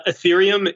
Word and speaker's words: Ethereum: Ethereum is the name of the network, yeah, and Ether is the name of the token.